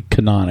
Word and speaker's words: Kanaan: The first one was Indy race car driver, I should say, Tony Kanaan. I think that's how you pronounce his last name. It is now. That's how we pronounce it.